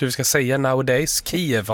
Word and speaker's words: Kyiv: Men det är som kniv. Vi ska tänka kniv när vi säger Kiev.